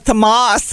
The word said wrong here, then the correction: Thames: River. – The Thames River in England.